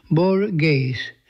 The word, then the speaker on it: Borges: Anglophones tend to pronounce the Spanish language author's name Borges, and that is acceptable, as are all attempts at a more authentic pronunciation involving the voiceless velar fricative that attests to the long march of the vocalization.